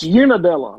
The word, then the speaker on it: Unadilla: You are the mayor of Unadilla, Georgia. Population of 1,500. – 1,500. – And it's Unadilla, like Una. – Like Una, like Unabrow. Unadilla.